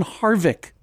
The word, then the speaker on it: Harvick: Kevin Harvick.